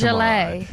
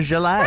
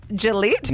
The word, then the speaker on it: gilet: That's a gilet. – Right. – Are you saying gilet like Gillette? – No. – But fancy? – How do you spell it? – G-I-L-E-T. So almost like Gillette, but.